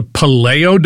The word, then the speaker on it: Paleo: I saw an article that mentioned that corner table is tied into the paleo diet. Did I say that right? – Paleo, sir.